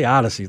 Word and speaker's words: Audacy: I say Odyssey like it's an O.